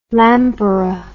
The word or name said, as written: lampara